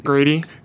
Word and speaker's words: Grady: Like, Grody... I said his name wrong again. – Grady.